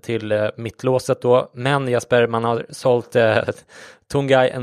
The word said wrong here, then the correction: Tanguy: Ndombele till Tottenham. – Tanguy. – Tanguy, ja, du kan få ta det uttalet här.